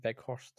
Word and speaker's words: Weghorst: I know a couple fell through, but the one that kind of came through is Weghorst. Is that his name? Weghorst, I think. – It sounds like a pretty good pronunciation.